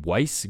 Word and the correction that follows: Wyc: Grausbeck? – Wick. – Wick. Damn, I whiffed on that one. Wow. – Wyc. W-Y-C.